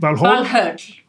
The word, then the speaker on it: Valhöll: One of the things that on the internet searches, people just seem desperate to know about is Valhalla, which should be pronounced Valhall. – Valhöll. – Okay. – Yeah, in modern Icelandic.